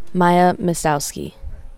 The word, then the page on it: Maya Zmistowski: Z is silent